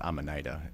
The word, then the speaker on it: Amanita: Well, Amanita, I think it's technically pronounced Amanita. It's a genus of mushrooms, which is poisonous.